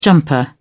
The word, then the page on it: jumper: British English